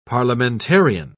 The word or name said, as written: parliamentarian